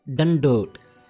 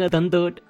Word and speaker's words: dandot: The word is dandot. – Dandot? – You are saying a dandot. Dandot. It's a dad, not a dadda.